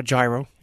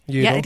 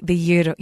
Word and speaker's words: gyro: Not gyro, gyro. – Yeah, I've been trying to tell people for years that it is pronounced the gyro, gyro, right? – Gyro, yes. From gyro meaning circular.